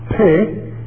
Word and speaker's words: Pe: We're going to start with the verse 129, and you'll notice that the Hebrew letter P are pronounced pe, introduces this particular part of the parable.